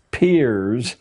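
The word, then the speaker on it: Peirce: He was, it's pronounced Pierce, but it's spelled as if it were Pierce.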